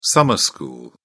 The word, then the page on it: summer school: British English